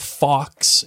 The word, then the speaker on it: Fawkes: Fox is spelled F-A-W-K-E-S.